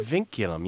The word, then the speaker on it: vinculum: Vinculum, yeah. It's a great Scrabble word, by the way. V-I-N-C-U-L-U-M.